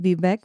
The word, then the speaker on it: Vivek: Da hat er im Englischen gesagt, sein Vorname Vivek würde sich auf Cake, also auf Kuchen reimen.